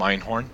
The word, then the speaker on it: Einhorn: My name is Dom Einhorn. Last name is spelled E-I-N-H-O-R-N.